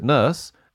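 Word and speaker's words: Nourse: The original book that used the title Blade Runner was by this guy, Alan Norse. I pronounce it Nurse.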